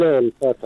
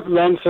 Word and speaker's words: الف: خب اون فاعل الفت است، الفت کننده. – چه قسم تلفظ کنیم صحیح؟ – الف نه لنف الف. – الف خب صحیح شد، درست است.